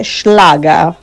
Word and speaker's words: Schlager: Buongiorno cari, avete detto bene, si dice Schlager.